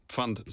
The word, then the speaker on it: Pfand: Pfand. Is this right? How do I say that? – Pfand is perfect. – Pfand, okay.